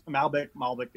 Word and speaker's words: Malbec: I'm pronouncing that correctly, right? – Yeah, that's Malbec. – It's Malbec, yes.